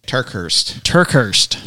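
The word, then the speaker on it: TerKeurst: How do you say that name? – Turkhurst. Yeah. Lisa Turkhurst.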